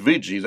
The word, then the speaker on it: Ouija: Now, it says Ouija, that's the way it's pronounced, but it's spelled O-U-I-J-A, which is important.